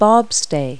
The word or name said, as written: bobstay